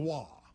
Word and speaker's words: Roy: Yeah, R-O-Y, but it's pronounced Raw.